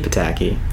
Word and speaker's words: Pataki: I think you're the only person I've ever heard pronounce it as Pataki. – Is it Pataki? – Pataki. – Shit. – That's how I've always heard it pronounced.